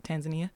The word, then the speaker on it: Tanzania: It's called Tanzania in different ways of how you pronounce it, but we over here call it Tanzania.